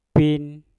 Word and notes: pin: mpur

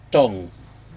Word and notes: tog: Aneityum

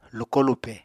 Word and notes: lukó-lupè: Apatani

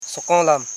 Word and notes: sokonlam: so.kon.lam

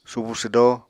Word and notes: subu swdó: Apatani

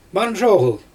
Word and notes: mazhoGyl: Xyzyl

